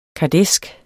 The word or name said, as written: kardæsk